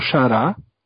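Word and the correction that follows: Chará: Chara, né, na verdade, corrigindo aqui a, a pronúncia, e o Théo Gutierrez, um veterano bem conhecido de todos.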